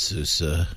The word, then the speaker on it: SUSE: Sorry, Steve, that's pronounced SUSE.